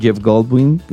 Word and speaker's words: Jeff Goldblum: Había gente como Meghan Trainor, Jeff Goldblum, no sé si se pronuncia así. – Sí, está muy bien dicho, por favor.